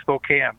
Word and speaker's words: Spokane: And with that, we go to Spokane, Washington. Go right ahead. Welcome to the show. – It's actually Spokane. I know everybody pronounces that with a wrong A.